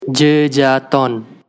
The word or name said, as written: Jejaton